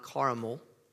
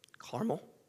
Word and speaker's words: Carmel: Last week, we journeyed up to Mount Carmel, not Carmel, Carmel, two syllables. You know what I'm about to listen to that? It is so hard to hear the two versus three syllables. Caramel, Carmel.